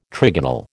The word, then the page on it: trigonal: American English